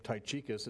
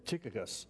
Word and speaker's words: Tychicus: I've been mispronouncing this one name, Tychicus. It's actually Tychicus and Trophimus of Asia there in Ephesus.